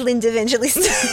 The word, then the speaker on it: Linda Evangelista: You look like Linda Evangelista.